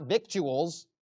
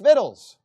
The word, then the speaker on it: victuals: It's not victuals, it's vittles.